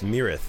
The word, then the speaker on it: Myrath: I always worry I'm pronouncing that wrong, because it looks like Myrith, but it's Mirith, I think. Right, Steven? M-Y-R-A-T-H.